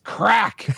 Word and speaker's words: craic: Craic is pronounced crack.